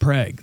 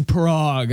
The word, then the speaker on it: Prague: Yeah, because, like, you know, Prague here in Nebraska, it's pronounced Prague. That's how they spelled it. – Right. – But there'll be a lot of people that don't know that, so they'll go, it's Prague.